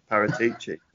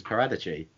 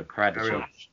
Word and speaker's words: Paratici: Lady Gaga's Paparazzi, because it rhymes with Paratici. Well, it doesn't. – Because it's Paradici. – Paratici? No. – No, Paradici.